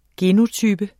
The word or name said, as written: genotype